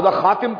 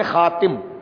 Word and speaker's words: خاتم: کبھی یوں ہوتا ہے کہ تا کے نیچے زیر ہوتا ہے تو پڑھتے خاتم، ٹھیک ہے. کبھی یوں ہوتا ہے کہ تا کے اوپر زبر ہوتا ہے تو پڑھتے خاتم. اب یہ دو قسم کے تلفظ قرآن میں اور دونوں قراءتیں جائز ہیں.